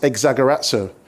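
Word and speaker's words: exagerazo (ἐξαγοράζω): The reason why I got you to repeat that word redeeming is because that word redeeming is taken from a Greek word which is pronounced exagerazo.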